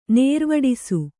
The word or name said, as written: ನೇರ್ವಡಿಸು